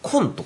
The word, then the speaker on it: 今: パッと思いつくのは、まあ、高校の時に、コンとかね、え今と書いてコンとか、あと、穴に沢と書いて穴沢とかね、穴沢くんとかいましたけどもね。